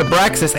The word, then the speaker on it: Abraxas: I pronounce it Abraxas.